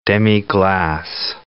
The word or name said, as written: demi-glace